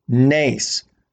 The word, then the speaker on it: Nes: Spells Nes, N-E-S, pronounced Nace, which is miracle in Hebrew.